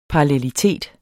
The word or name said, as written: parallelitet